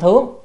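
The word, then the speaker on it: thướng (上): Rồi nhược linh thướng, chữ này là thướng chứ không phải thượng, chữ thượng nhưng mà đọc âm thướng, thướng là lên, đi lên á, động từ, còn thượng là danh từ ở trên à, hoặc là giới từ ở trên, nhưng mà đây phải đọc là thướng, thướng, cho nên một chữ nhưng mà phải đọc âm khác nhau thì nghĩa khác nhau.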